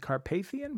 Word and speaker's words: Carpathian: Am I saying that correctly? – I think Carpathian, but like where Vigo, remember Vigo from Ghostbusters? Vigo the Carpathian? – Oh, yes, I do remember Vigo.